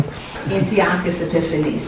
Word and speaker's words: Antiochus Epiphanes: I'll say Antiochus Epiphanes, and I butchered it, I'm sure. Didn't I? – Antiochus Epiphanes. – Okay, so that's probably the more Hebrew way to say it, but I don't know. Oh, it's Greek. Okay.